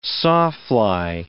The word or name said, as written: sawfly